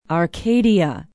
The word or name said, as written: arcadia